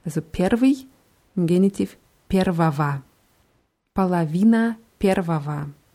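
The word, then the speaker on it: Palavina (половина): Die Hälfte heißt Palavina. – Palavina. – Ja, mit zwei O's vorne geschrieben Polowina, werden aber beide WAs ausgesprochen, Palawina.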